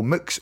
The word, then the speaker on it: Mx: And it is pronounced Mix.